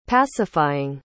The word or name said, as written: pacifying